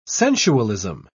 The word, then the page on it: sensualism: British English